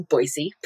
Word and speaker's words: Boise: the inside people in Boise, pronounced it with an S sound, so Boise.